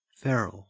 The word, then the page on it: feral: Australian English